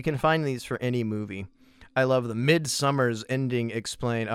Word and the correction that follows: Midsommar: Oh, I'm sorry, I've said that wrong. Midsommar's ending explained.